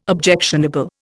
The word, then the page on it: objectionable: Indian